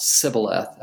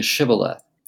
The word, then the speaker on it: Shibboleth: which is the S sound, Sibboleth, as opposed to Shibboleth.